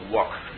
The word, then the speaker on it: Wok: dieser Ausdruck Wok, nicht, das ist die kantonesische Aussprache des hochchinesischen Wortes Guo, also Kochtopf.